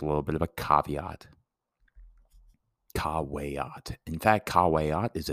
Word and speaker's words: caveat: It's a Jessup subjunctive, so I'll have to figure out the exact correct translation from caveat. Caveat, remember, because V's are pronounced as W's.